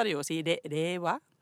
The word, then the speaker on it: Reiwa: Det heter altså da rewa. Og det skrives R-E-I-W-A. Så hvis du skal si det med norsk uttale, så må det jo nesten bli Reiva.